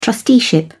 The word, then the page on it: trusteeship: British English